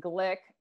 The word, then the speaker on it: Glick: You could find me at The Lauren Glick, which my last name is spelled G-L-I-C-K, on Instagram.